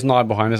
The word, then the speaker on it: Nibelheim: Is it Nibelheim? It's Nibelheim. I mean, that's how you pronounce it.